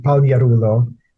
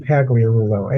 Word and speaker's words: Pagliarulo: Well, the true pronunciation, the G really is silent, so you pronounce it Pagliarulo, but here in the States, it's always pronounced Pagliarulo.